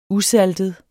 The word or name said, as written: usaltet